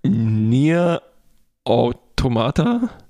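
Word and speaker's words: NieR: Automata: Sprich du mal aus. Wir machen es auf 3, 2, 1. Nier Automata? So würde ich es auch sagen. Okay, gut. – Jedenfalls schreibt man es N-E-R.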